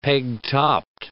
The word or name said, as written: peg-topped